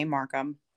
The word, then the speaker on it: Markham: Markham.